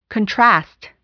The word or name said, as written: contrast